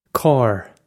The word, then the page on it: cár: Irish